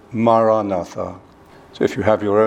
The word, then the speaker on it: Maranatha: If you choose this word, say it as four syllables Ma Ra Na Tha.